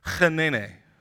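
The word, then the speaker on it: Hineni: Now, what's interesting is Moses sees the presence of the Lord in verse 4, and he utters this word, and we'll say it right, Chenene. I know, I did it right.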